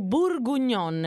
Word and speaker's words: bourguignon: Quindi manzo bourguignon, è corretta la mia pronuncia? – Esatto, esatto, esatto, esatto. Poi, vabbè, l'accento francese ovviamente manca, però più o meno è quello. – Manzo bourguignon!